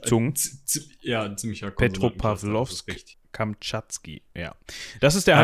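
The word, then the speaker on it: Petropawlowsk-Kamtschatski: Ähm, also der Heimathafen des U-Boots nennt sich äh, Petropa Petropavlovsk Kamatschki. Ist das richtig nee. ausgesprochen? Petropavlovsk Kamtschatski. Das ist. Kamtschatski, ah, okay, okay.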